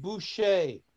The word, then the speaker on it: Boucher: I guess you could say, go look at the paintings. It's pronounced Boucher.